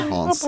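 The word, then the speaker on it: ha (ห้า): Nej. I Thailand så skriver man 55555. För att de uttalar 5, ha.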